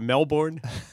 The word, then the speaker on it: Melbourne: but not one person tells you that it's not pronounced Melbourne, and as an American, they immediately would make fun of you. Oh, it's Melbourne, it's Melbourne.